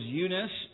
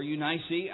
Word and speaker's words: Eunice: Actually, it was his grandmother was Lois, and his mother's name was Eunice, or Eunice, I've heard it pronounced too.